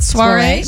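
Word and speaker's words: soirée: Soiree.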